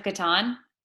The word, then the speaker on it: Catan: We played this game called, I think it's pronounced Catan. – Settlers of Catan? – Yeah. The C-A-T-A-N.